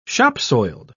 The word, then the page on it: shopsoiled: British English